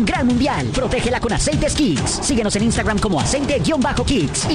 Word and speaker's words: Kixx: Así como los campeones se preparan para ganar, protege tu moto con Kicks, K-I-X-X. Aceites sintéticos y semisintéticos para un máximo rendimiento. Síguenos ya mismo en Instagram como @aceite_kicks. El Kicks se escribe K-I-X-X.